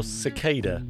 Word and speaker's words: cicada: Anna's a wife, mother, author, speaker, and cicada supporter, or cicada, as it's pronounced over the pond.